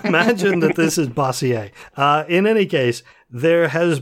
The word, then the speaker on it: Bossier: Meanwhile, down in Louisiana, I'm going to assume, since this is Louisiana, that that parish is pronounced Bossier, but I don't know that for a fact. – In Pennsylvania, it would be Bossier. – Bossier. Or Bossier. – Bossier, there you go.